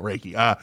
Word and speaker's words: Reiki: but a lot of people mispronounce this one. yeah. So, you know, we did our homework and looked up that pronunciation. No, Reiki.